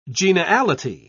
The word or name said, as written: geniality